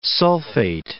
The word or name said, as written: sulfate